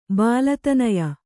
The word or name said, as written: ಬಾಲತನಯ